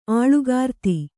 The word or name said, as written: ಆಳುಗಾರ್ತಿ